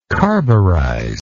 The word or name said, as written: carburizer